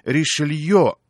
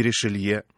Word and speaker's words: Ришелье: Но кто, например, знает, что правильно говорить «Ришелье», а не «Ришелье», «Монтескье», а не «Монтескье», а великий русский математик Пафнутий Львович Чебышев на самом деле Чебышев.